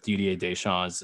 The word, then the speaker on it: Didier Deschamps: And that's the risk that they run with Didier Deschamps as the coach.